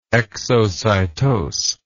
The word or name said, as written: exocytose